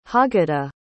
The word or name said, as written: haggadah